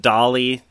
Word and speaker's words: DALL-E: it's probably pronounced Dolly, like the artist, but it's spelled Dall-E.